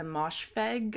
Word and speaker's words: Moshfegh: My Year of Rest and Relaxation by Otessa Moshfegh.